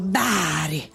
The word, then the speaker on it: Bari: Bari. – No, bravo. – Però non l'hai detto bene. Quando dici Bari lo devi dire bello rotondo. Bari.